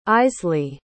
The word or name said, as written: Eisley